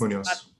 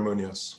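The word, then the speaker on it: Muñoz: On the Broadways, started out as an alternate for, I'm not going to say his name right, Javier, what's Munoz. – Say it again. – Javier Munoz.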